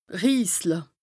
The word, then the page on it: riisle: Bas-Rhin; Strasbourg